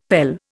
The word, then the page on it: pele: Indian